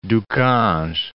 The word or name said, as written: Du Cange